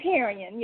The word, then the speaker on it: Harrine: Is it Harrine? – It's Harrine, yes.